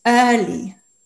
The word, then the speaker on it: early: Dann hast du aber zum Beispiel auch noch das ähm, Früh, ist Early, E-A-R-L-Y. Plötzlich wird es wie ein Ö ausgesprochen.